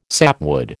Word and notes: sapwood: American English

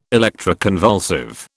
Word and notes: electroconvulsive: British English

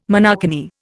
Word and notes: monokini: Indian

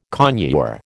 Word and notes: conure: American English

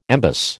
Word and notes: embus: American English

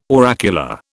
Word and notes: oracular: British English

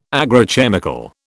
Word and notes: agrochemical: British English

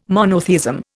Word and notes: monotheism: Indian

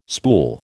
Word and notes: spool: American English

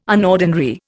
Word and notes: unordinary: Indian

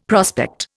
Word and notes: prospect: Indian

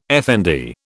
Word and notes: effendi: British English

